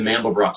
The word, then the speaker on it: Mandelbrot: For those of you who can't see the screen, that's spelled M-A-N-D-E-L-B-R-O-T. It's like George Frederick Handel, except it's Mandel and then B-R-O-T at the end, the Mandelbrot set.